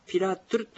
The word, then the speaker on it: ピラトゥル: うん、ピラなので、まあ、ピラトゥルというのが元の形です。ではですね、発音してみましょう。ピラトゥル。